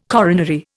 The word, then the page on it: coronary: Indian